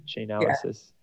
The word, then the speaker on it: Chainalysis: Chainalysis.